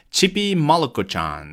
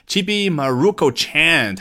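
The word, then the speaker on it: ちびまる子ちゃん: 那开头这个 Chibi Malako-chan，那是接近于日语的发音啊，我个人只是学过几十个小时的日语，不敢说发音非常的标准，但至少比绝大部分美国人发的要标准啊。这个美国人如果说 Chibi Maruko Chan，他已经觉得我的天呐 i v e just Japanese it up 啊，他觉得刚刚说的这个日语已经标准的不行了。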